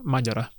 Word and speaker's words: Мадяра: Залишу посилання, якщо хтось не дивився на останні епізоди про Угорщину, про окремий епізод про Орбана, окремий епізод про Мадяра, Мадяра, правильно ставити наголос буде.